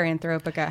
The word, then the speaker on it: Anthropica: I mean, Anthropica, is it Anthropica or Anthropica? How do you want it pronounced? – I say Anthropica because the way I would say like anthropic principle.